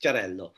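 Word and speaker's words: Xarel·lo: Xarel-LO is how you spell that. So it looks like Axarello, but it's pronounced Cerello.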